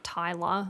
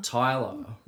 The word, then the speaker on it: Tyla: Be pronouncing it wrong because it's like T-Y-L-A. – Ah. – It'd be like Tyler. – Tyler, right.